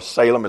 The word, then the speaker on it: Salim: This Aenon and Salim, or Salem as some would pronounce it.